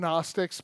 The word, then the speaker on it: Gnostics: I like to call them Gnostics, but the G is silent.